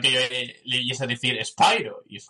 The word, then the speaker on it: Spyro: Pues me sorprendió escuchar que llamaban a Spyro ¡Spyro! Y es como, hostia, pues claro, tiene razón, tiene lógica que ese SPI se pronuncie Spyro.